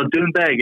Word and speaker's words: Doonbeg: It was Doonbeg, now known as Trump International Golf Links. Did I say it wrong? – No, Doonbeg. Yeah, that's right.